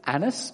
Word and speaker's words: Annas: So the next day, their rulers, elders, and scribes assembled in Jerusalem with, I always say this wrong, I think it's Annas, but I always feel like saying an ass, and he is a bit of a donkey, as we'll see.